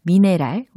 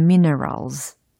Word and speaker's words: minerals: They are rich in minerals. 아하, 신경을 써서 발음을 했더니, 미네랄, 이게 아니고, minerals.